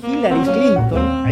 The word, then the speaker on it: Hillary Clinton: Aparte de estar eh, Hillary Clinton, Hillary Clinton, ahí se pronuncia mejor, que fue candidata presidencial en la elección pasada y que perdió ante el actual presidente de Estados Unidos, fue primera dama.